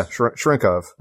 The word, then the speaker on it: Cherenkov: Cherenkov radiation? Cherenkov? – Yeah, Cherenkov.